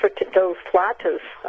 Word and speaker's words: flauta: Well, I'm making those flautas.